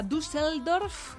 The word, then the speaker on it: Düsseldorf: Que vela ya armas, va a viajar a primera hora de la tarde a Düsseldorf, que es el... – Qué bien pronuncias. – Düsseldorf,